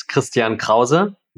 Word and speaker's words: Christian Krause: So my name in German is Christian Krause. So we just pronounce it a little bit more harshly. We pronounce all of the letters very clearly in German, which is why there's all these jokes about German language that it sounds very angry.